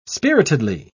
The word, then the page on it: spiritedly: British English